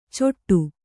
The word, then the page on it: ಚೊಟ್ಟು: coṭṭu